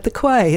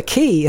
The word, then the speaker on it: Quay: at the quay, and they came running upstairs, it's the key. And I'm like, well, why don't you spell it key? So yes, it's LV18, the key, spelled Quay, Heritage.